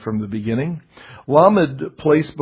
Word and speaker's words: Lamed: Well, Lamed, that's how you would pronounce the letter. Lamed, with a stress on the first syllable.